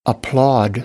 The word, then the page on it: applaud: American English